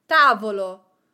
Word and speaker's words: tavolo: Tavolo. Tavolo means table. The accent is on ta, ta, and not on vo, vo, which is the syllable before the last one.